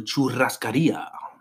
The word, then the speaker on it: churrascaria: On Valentine's Day, we stole away from work, went and watched a movie, and then yesterday went to a churrascaria.